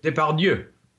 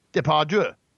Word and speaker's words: Depardieu: But I also would like to point out, just to validate this story, that Mr. Depardieu, how do you say that? How do you say that? I've never been able to say it. – Depardieu. – Depardieu. Yeah. Yeah, that'll do.